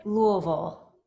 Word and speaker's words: Louisville: And we, the two of us, know how to pronounce it, Louisville.